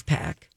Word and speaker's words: Paak: It's Pac. – It's spelled P-A-A-K, but let's go with Pac.